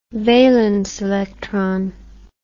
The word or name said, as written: valence electron